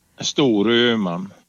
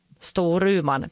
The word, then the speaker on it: Storuman: Hur uttalar man det? – Storuman. – Storuman, precis. Ah.